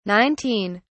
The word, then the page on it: 19: British English